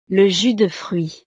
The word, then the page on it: le jus de fruit: frances